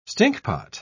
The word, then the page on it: stinkpot: British English